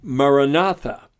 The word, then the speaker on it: Maranatha: Maranatha. Now, the word Maranatha is found in Paul's writings, and it means come, Lord Jesus. And so they instruct people to stretch it out into four syllables, Ma-ra-na-tha, and to say that over and over and over hundreds of times in order to be an aid in meditation.